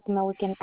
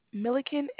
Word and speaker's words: Milliken: Location is going to be 2025 South Milliken Avenue, Ontario, California, 91761. – And can you spell the street name South Malkin?